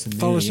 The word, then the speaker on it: Thoros: Is that how you say his name? – Thoros of Mir. Yeah. Paul Coe was great in it.